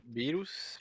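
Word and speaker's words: Beerus: En vez de eso, los dataminers de Fortnite han encontrado información de que Goku, Vegeta y Virus, o Verus, no recuerdo cómo se pronuncia. – Virus, Virus. – Virus. – Sí, como cerveza, ¿no? – Como cerveza.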